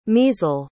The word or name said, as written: measle